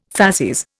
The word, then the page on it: facies: Indian